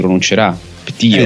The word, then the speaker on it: PT: Avendo giocato a PT, questo grande demo, come si dice, PT si pronuncerà PT, sì. Come porca troia, esatto.